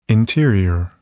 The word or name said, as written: interior